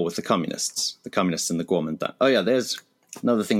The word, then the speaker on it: Kuomintang: The K should be pronounced as a G and the T as a D. So the KMT is the Kuomintang.